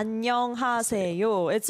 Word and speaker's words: 안녕하세요: 안녕하세요. It's a 5-syllable word.